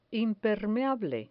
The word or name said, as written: impermeable